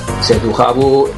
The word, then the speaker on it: Setujabu: Setujabu, la pronunciación nuestra era de Setujabu, tenía que sonar un poco francés. Y el nombre es fácil, es una mezcla, una composición de nuestros nombres. J-A-V, C, la T, y así lo mezclamos y añadimos unos vocales y así salió Setujabu.